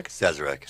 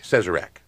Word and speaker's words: Sazerac: And that's why, I'm sorry, what's the right pronunciation for this dance? Sazerac? – Sazerac. – Sazerac. Okay.